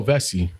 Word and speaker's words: Vecsey: Oh, Vessi. – Vessi. – Yeah. – It's not Vexy? – Nah. – Vessi. Vessi. – Yeah, it's a soft C.